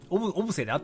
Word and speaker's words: 小布施: へえ。秋といえば包丁姫が愛好している栗の季節、栗というとやはり、茨城のつくば、東京多摩、地域の丹沢栗、丹沢、長野の小布施、これはぎ、え、これはん、小布施で合ってるの、あ、読み方書いてくれてました、ありがとうございます。